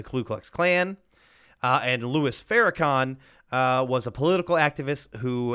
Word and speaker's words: Farrakhan: And he says it's like David Duke and Farrakhan are together, which I think is actually pronounced Farrakhan.